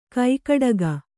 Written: ಕೈಗಡಿಕ

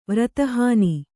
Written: ವ್ರತಹಾನಿ

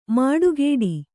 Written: ಮಡಿಕಟ್ಟು

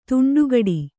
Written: ದಿಂಡುಗೆಡೆ

